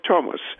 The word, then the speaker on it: Thomas: We still pronounce the name of the river Thames, even though it is spelled with a TH. And the same is true of Thomas.